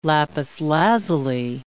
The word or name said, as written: lapis lazuli